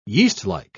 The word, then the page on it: yeastlike: English; British English